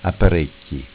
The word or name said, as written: Apparecchj